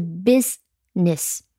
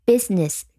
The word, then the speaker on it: business: 另外要注意的是读音，读音呢是 biz，没有 z，是 business，只是一个元音 biz。 ness，所以它的重音在前面。 business，再听一遍它的拼写，b u s i n e s s。